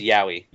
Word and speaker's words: yaoi: It's pronounced yaoi.